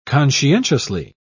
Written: conscientiously

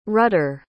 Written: rutter

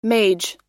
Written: mage

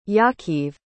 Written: yakiv

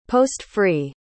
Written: post-free

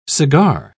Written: cigar

